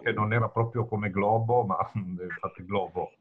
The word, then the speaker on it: Glovo: Glovo. G-Glovo. G-L-O, Verona, Otranto.